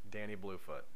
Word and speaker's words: Danny Bluefoot: Danny Bluefoot. – It's spelled just like it sounds. – Yep, no spaces, nothing.